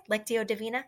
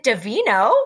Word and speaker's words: Lectio Divina: Did I say that right? Is that right? Lectio Divina? Yeah, that's right. – I think so, yes. – I was like, is it Divino? No.